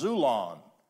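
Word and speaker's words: xulon: But the other word that is used in the New Testament more than that is the word zulon, spelled with an X, but it's pronounced zulon.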